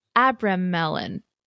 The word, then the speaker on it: Abramelin: of the original book of Abramelin. – How do you spell it? – A-B-R-A-M-E-L-I-N. Abramelin, the mage. It's almost like Abraham, but it's not.